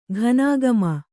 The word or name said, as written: ಘನಾಗಮ